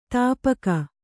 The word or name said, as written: ತುಪಾಕಿ